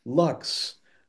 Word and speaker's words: luxe: Hey, Thomas Hine, yeah, I can't remember where exactly where you're like, yeah, it's Luxe, like deluxe, but it has the E just for class, silent E.